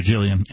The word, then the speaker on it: Gillian: I saw a series recently with Gilligan Anderson. I'm sorry, Gillian Anderson. She spells it with a G, so I was pronouncing it wrong. – Oh, yeah, Gil. I mean, Jill.